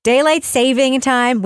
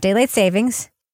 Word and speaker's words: daylight saving time: Listen, the space-time continuum, daylight saving time, which by the way is how you say it, not daylight savings.